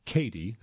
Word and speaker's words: Cady: But when Katie, that's spelled C-A-D-Y, devises a plan to end Regina's reign, she learns the hard way that you can't cross a queen bee without getting stung.